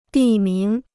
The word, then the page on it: 地名: dì míng